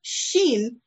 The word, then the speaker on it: Shin: It's spelled S-H-I-N as in Shin, but Shin, S-H-I-N.